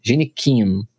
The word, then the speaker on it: Gene Kim: do Gene Quinn. Gene Kim, Desculpa aí a pronúncia. Inglês não perfeito.